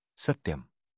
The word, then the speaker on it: Satyam: And of the Brahman, the name is the true, Satyam. This name, Satyam, consists of three syllables, Sat, Ti, Yam. Sat signifies the immortal, Ti, the mortal, and Yam, which means, by this the two are held together.